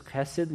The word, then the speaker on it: chesed: His own character is defined by this loving kindness, this chesed, that's how you would pronounce it, this chesed love.